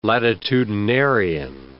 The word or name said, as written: latitudinarian